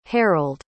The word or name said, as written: harald